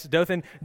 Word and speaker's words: Dothan: No, so Dothan, Alabama, am I saying that right? That reminds me of, what was the conch? What was that? You guys remember that? Right, the conch? See, I still can't say it right. So wild guess is Dothan. Yes, Dothan. Dothan is 100 miles that way.